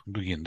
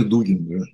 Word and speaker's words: Дугин: Так вот, профессор Дугин, или Дугин, я по сей день не знаю, как правильно ударение ставить. Вот, он... А, Дугин, да? – Вроде Дугин, Дугин, да? – да.